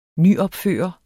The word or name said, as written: nyopføre